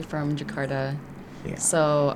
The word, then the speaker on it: Jakarta: Am I saying it right? Jakarta, Jakarta, sorry. – Perfect.